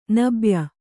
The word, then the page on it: ನಬ್ಯ: nabya